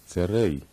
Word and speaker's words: Tzerei: Tzerei is pronounced like the EY in they.